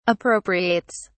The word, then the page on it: appropriates: British English